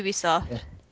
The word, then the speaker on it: Ubisoft: Ubisoft.